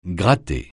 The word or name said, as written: gratter